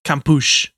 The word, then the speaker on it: Kampusch: But another example of this, Natasha Kampusch. – Yeah, that's how I would pronounce that.